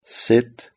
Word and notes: sit: British English

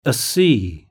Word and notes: a sea: British English

